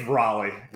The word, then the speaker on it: Raleigh: It's Raleigh.